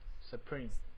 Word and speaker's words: Supreme: 其实很多是炒作出来，因为我之前就是有听到朋友还是上网有看到一个一个讯息，就是有关那个那个发音怎么发音？Super Me 吗？Supreme。